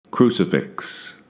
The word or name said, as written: Crucifix